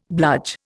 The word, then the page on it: bludge: Indian